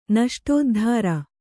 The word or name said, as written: ನಷ್ಟೋದ್ಧಾರ